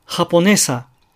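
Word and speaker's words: japonesa: 例えば、ハポネサという単語がありますね。これはねという音節にアクセントがあるわけですが、いつでもねが高くハポネサとだけ発音されるとは実は限らないんです。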